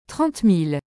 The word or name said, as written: 30000